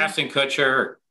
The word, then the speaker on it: Kutcher: Ashton Kutcher.